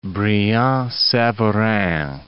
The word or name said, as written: Brillat-Savarin